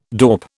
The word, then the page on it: dorp: British English